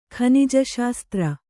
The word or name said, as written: ಖನಿಜಶಾಸ್ತ್ರ